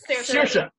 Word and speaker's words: Saoirse: Shori, Sarah, Ceres Ronan, is that right? Uh, yeah, Saoirse.